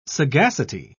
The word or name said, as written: sagacity